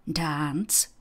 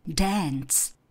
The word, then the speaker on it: dance: A u toho slovíčka family, podobně jako třeba u slovíčka happy nebo dance, apple, se mě studenti často ptají, proč vyslovuju happy, když oni to znají jako happy. Proč říkám dance, když oni to jasně znají jako dance. A odpověď je jednoduchá, oboje je správně. Záleží jenom na tom, jestli se víc přikláníte k britské výslovnosti nebo k americké výslovnosti.